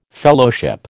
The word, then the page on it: Fellowship: American English